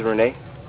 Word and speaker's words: Rene: This is Renee. – Renee, and how do you spell that? – R-E-N-E.